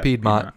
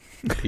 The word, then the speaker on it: Piedmont: Piedmont. Wow, I butchered that. Piedmont. – Piedmont. Piedmont. Pet,